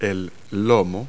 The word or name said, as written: loin